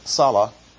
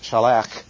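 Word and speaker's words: shalach (שלח): And our English just has salah. Actually, in Hebrew that would be pronounced shalach.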